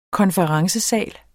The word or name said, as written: konferencesal